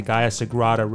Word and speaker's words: Gaia Sagrada: Everybody, we'll be right back with more of Christine, and we're going to learn more about the Gaia Sagrada Retreat.